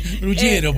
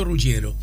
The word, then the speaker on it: Ruggiero: Tanto que dije de mi apellido, no sé si lo estoy pronunciando bien. – Ruggiero, puede ser.